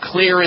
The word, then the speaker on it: Xlear: Find the one that's best for you, and Clear is spelled X-L-E-A-R. That stands for the X, and it stands for the xylitol.